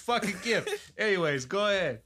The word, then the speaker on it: GIF: It's GIF. Anyways, go ahead. – That's wild. That is hashtag wild. – GIF. – That's really how you say it.